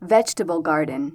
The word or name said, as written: vegatable garden